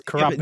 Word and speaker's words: Kurapika: When you pronounce his name, can you pronounce the P as part of the Kuro? – What do you mean? – So, you know how you're saying Kuro-pika? Is that what you're saying? – Kuro-pika? Yeah. – Yes. – Move the P over to the Kuro. Try that. Try pronouncing it rather than Kurapika. Kurapika. – Because that's what it is. It's Kurapika.